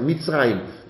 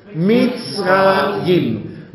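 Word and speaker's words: Mizrajim: Mitzrayim. Martha Ida Zeppelin, Richard Anton, Joachim Ida Martha. Mitzrayim. Können wir es alle mal aussprechen, Conny? Mitzrayim.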